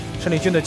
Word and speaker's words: 申立军: 申立军，他的名字呢还要跟大家再做一下解释，虽然说报名的时候汉语拼音是陈，但是呢申立军的家人包括他本人都是读慎的这个读音，所以我们一直是叫申立军。